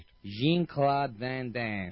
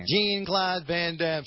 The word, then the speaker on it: Jean-Claude Van Damme: I asked him if I was saying his name right. – Jean-Claude Van Damme. – Yeah. Jean-Claude Van Damme.